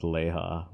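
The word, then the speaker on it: LeHah: He's going to be They pronounce it Leha in the BBC audio. So I'm going to go with Leha, like where they're like Leha, Defiler, you know.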